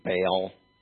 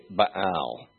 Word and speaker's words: Baal: I'll tell you, this may kind of ruin this word for you, but you know the false god, we say in an anglicized way, we say Baal. It's literally Baal, is how it would be pronounced in Hebrew.